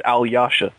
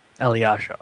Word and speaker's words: Alyosha: I think I've heard DeMatteis pronounce it, or DeMatteis pronounce it Alyosha. – Alyosha, okay.